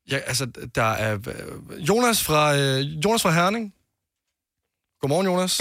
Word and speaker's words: Hyuna: Det er navnet på dansk, siger man Jonas, fordi at H udtalt stumt. Men det er H-Y-U-N-A.